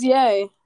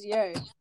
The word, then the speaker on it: Ye: it's yay, it's yay. I looked it up. It's pronounced yay.